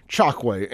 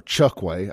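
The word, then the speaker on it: Chokwe: The Jackson mayor, Chakwe Antar Lumumba, or Chukwe. Apparently, I've been saying his name wrong for 4 or 5 years now. It's Chukwe, not Chakwe, but it's spelled Chakwe.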